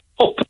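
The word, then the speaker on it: Höfn: And, you know, two nights ago he turned up in place called HOFN, which apparently in Icelandic is pronounced up.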